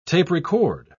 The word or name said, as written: tape-record